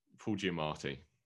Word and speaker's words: Giamatti: Paul Giamatti, yeah. – Did I say it wrong? – No, you did. No, I was agreeing with you.